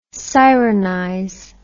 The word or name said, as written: sironize